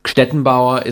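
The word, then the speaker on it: Gstettenbauer: Ich heiße Stettenbauer. Wie schreibt man denn das? – Ja. Es ist wirklich so, es ist ein G am Anfang, GST, und man, Stettenbauer ist das so, und ich hätte niemals gedacht, dass der Name sich tatsächlich so durchsetzt.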